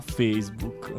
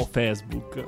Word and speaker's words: Facebook: Dani, pagina Facebook, no Facebook.